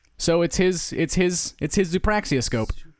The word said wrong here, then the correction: zoopraxiscope: Zoopraxoscope? Did I don't know. – add a syllable? Yeah, zoopraxoscope, I'm sorry.